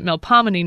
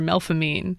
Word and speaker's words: Melpomene: In English, we pronounce it Melpomene normally, but here in the city, it's pronounced either Melpomene or Melfamine. There is no H, but some people do pronounce it as though there were an H following that P, and some people are convinced that it was spelled that way at some point.